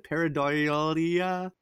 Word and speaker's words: pareidolia: Pareidolia.